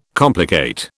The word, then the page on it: complicate: British English